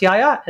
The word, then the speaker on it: Yahya: I was worried that I was going to fuck that up, but I looked up how to pronounce Yahya, and that's what it said, and that's what I'm sticking with.